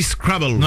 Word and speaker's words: Scrabble: On peut dire effectivement que B-L-E, ça fait bleu, mais ça fait aussi bull. Parce que Scrabble étant un mot anglais, on dit Scrabble.